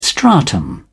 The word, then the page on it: stratum: British English